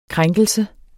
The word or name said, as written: krænkelse